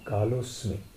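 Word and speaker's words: कालोऽस्मि: श्री भगवान वाच कालो अस्मि कालो अस्मि ने कालोस्मि कालो जब हम भेद करते हैं तब अस्मि कहते हैं, परंतु श्लोक के उच्चारण के समय वो अवग्रह है ना, ऐसा है ना, तो उसमें कालोस्मि हम उच्चारण करते हैं। केवल शब्द भेद करके समझाते समय हम काल है अस्मि कहेंगे, उच्चारण के समय कालोस्मि।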